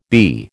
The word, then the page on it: Bi: American English